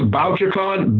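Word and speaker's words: Bouchercon: It's BokaCon, BokerCon. It's named after a guy named Anthony Boucher, who in the 20s and 30s was one of like the founding fathers of early crime writing.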